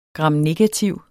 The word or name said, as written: gramnegativ